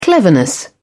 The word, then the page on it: cleverness: British English